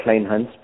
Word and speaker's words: Kleinhans: I mean, it really depends on which part of the world you come from. They have... They have a challenge pronouncing my surname in England, and where I'm from, South Africa, which is where I'm originally from, we say Kleinhans, but I usually tell people to say, just like you said, it, Kleinhans, as there, because it just makes it easier for folks from across the water.